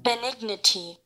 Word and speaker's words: benignity: Benignity. – Benignity. – Benignity. – Okay, yeah, so it sounds like dignity, but it's benignity.